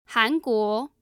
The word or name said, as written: hanguo